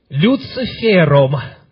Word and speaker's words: Люцифер: Люцифером. Люцифер. Ударение на последний слог.